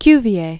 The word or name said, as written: Cuvier